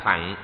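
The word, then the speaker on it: thặng: Đọc chữ thẳng nghĩa là cái xe. Theo đây phải đọc là thẳng mới đúng, song vì theo thói quen nên đọc là thừa. Đại Thừa có năm nghĩa.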